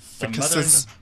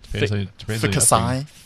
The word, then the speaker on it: Ficus: Ficuses? Ficusi? – Ficuses. – Ficus. – That's the right way to say it, right, Robin? – Ficus, I think, is more correct.